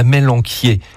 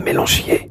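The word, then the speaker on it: amélanchier: Et alors aujourd'hui on voulait parler de la mélanquier. Qui s'écrit à mélanchier. Mais il ne faut pas le dire parce que ça fait marrer les enfants.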